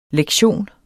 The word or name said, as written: lektion